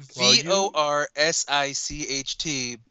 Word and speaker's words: Vorsicht: V O R S I C H T, but it's pronounced more like Vorsicht.